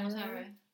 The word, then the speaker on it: Montero: Montero? – Yeah, Montero.